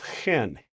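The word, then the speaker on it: chen (חן): Well, I'm going to pronounce it the way they do it in Hebrew, chen.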